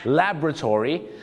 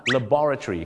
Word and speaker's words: laboratory: อเมริกาจะออกเสียงว่า laboratory the laboratory แต่ชาวอังกฤษจะออกเสียงว่า laboratory ครับ laboratory laboratory อเมริกา Laboratory ก็คือชาวอังกฤษ